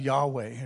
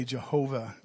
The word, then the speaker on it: Yahweh: And the first is a Hebrew word, which we pronounce Yahweh, or some people will say Jehovah.